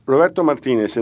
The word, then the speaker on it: Roberto Martinez: But he made the point that Roberto Martinez in this game did something pretty simple.